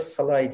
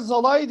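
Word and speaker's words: Szalai: Orada e, bu arada Salay diye telaffuz edildiğini başka programlarda da kullandım. Böyle telaffuz ediliyormuş dedim. – Ya bütün şey spiker bütün maç Zalai dedi abi yani çok zor bir şey değil ya bu internette forvoda falan var nasıl şey basınca Google'da falan sesli okuyor.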